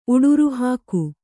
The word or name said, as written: ಉಡುರುಹಾಕು